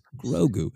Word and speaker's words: Grogu: It's not pronounced kid. It's Grogu.